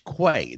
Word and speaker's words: Quay: Mike says to me, do you pronounce this Quay or Quay? I said it's Quay, the sage of Quay.